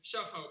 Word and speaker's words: Shahar: Yeah, it's okay, Shahar, because it's Shahar, but Shahar, it's okay. I know it's hard to pronounce.